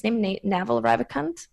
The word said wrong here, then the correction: Naval Ravikant: something like that. Mm-hmm. So this one was also Naval, like a brilliant book. – Naval Ravikant, yeah. – Naval, yes, that's the one.